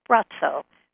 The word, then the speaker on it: Braco: B-R-A-C-O. Looks like Braco, but it's pronounced Brazzo.